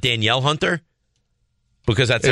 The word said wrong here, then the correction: Danielle Hunter: It's Daniil Hunter. Yeah. I know that, but it's spelled Danielle.